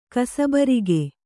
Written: ಕಸಬರಿಗೆ

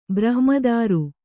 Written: ಬ್ರಹ್ಮದಾರು

